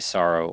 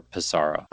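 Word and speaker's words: Psaro: And depending on which character you pick, they're either going to say Sorrow or Pizarro. – Ah. – So it is kind of it's debatable. You could pronounce it however you want. I like Sorrow because it sounds like the word sorrow, like S-A-R-R-O-W.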